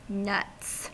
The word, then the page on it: nuts: American English